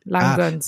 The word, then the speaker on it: Langgöns: Also ich wusste nicht, ob es Langgöns oder Lang, also die G ist doppelt, aber es ist so ähnlich wie Languste, Langgöns.